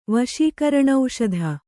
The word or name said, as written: ವಶೀಕರಣೌಷಧ